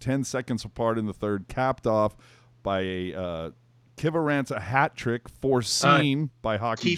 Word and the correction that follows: Kiviranta: Kiviranta, please get our Lord and Savior's name correctly. And everybody who's listening, AJ, how do you say his first name? – Sir? Yoel. – Yoel, not Joel. Kiviranta with the hat trick foreseen by me.